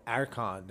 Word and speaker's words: Erkan: Like, Arkan. I'm like, what Arkan? I'm like, no, Arkan. They're like, okay, how do you spell that? I'm like, you know, E-R-K-A-N.